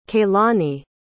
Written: kailani

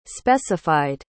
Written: specified

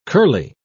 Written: curly

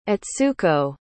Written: atsuko

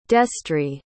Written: destry

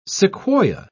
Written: sequoia

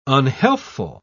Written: unhealthful

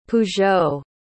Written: peugeot

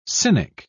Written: cynic

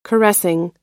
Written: caressing

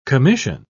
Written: commission

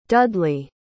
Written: dudley

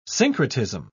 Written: syncretism

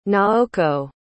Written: naoko

